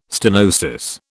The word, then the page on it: stenosis: British English